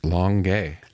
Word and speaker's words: Longueuil: Longueuil.